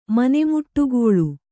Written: ಮನೆಮುಟ್ಟುಗೋಳು

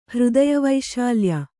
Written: ಹೃದಯವೈಶಾಲ್ಯ

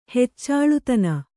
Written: ಹೆಚ್ಚಾಳುತನ

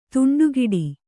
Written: ದಿಂಡುಗೆಡೆ

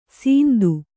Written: ಸಿಂಧು

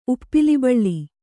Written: ಉಪ್ಪಿಲಿಬಳ್ಳಿ

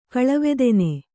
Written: ಕಳವೆದೆನೆ

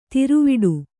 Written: ತ್ರಿವುಡೆ

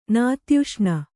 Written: ನಾತ್ಯುಷ್ಣ

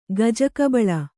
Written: ಗಜಕಬಳ

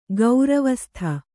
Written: ಗೌರವಸ್ಥೆ

